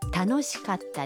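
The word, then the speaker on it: 楽しかった: در کلمه کاتا بین کا و تا یک مکس خیلی کوتاه داریم، یعنی در واقع صدای ت با تشدید تلفظ میشه. – تانوشیکاتا دس.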